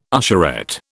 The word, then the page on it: usherette: British English